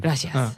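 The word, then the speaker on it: gracias: グラシアス。 – グラシアス。 – グラシアス。 – グラシアス。 – マドリード方言っていうか、もうマドリードの発音なので、うん、南米とかスペインの南の方の発音で言うのであれば、うん、グラシアス。 – あ、南米系のスペイン語で言ったらグラシアスなんだ。 – グラシアス、うん。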